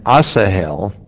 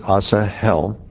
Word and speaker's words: Asahel: Verse 19, so Asahel, I think that's how you pronounce it, Asahel, pursued Abner. Of course, if you, the H, you get a little in there, Asahel, pursued Abner, and in going, he did not turn to the right hand or to the left hand following Abner.